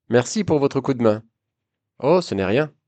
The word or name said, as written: ce nest rien